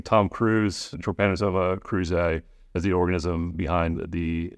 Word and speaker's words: Trypanosoma cruzi: Trypanosoma cruzi. – Yeah. Trypanosoma cruzi.